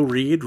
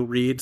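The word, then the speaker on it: Reid: I'm on Twitter at Joe Reed. Reed is spelled R-E-I-D. I am on Letterboxd, Joe Reed, spelled the same way. – And I am on Twitter at Crispy File. That's F-E-I-L, also on Letterboxd under the same name.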